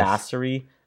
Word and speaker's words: Basseri: I get it all the time because my last name is spelled B-A-S-S-E-R-I. Sounds like Bossery. – It does. – Bassery. And it's actually like boss.